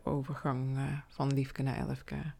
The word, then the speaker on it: LFK: LFK spreek je natuurlijk uit als drie losse letters en liefke is een woord.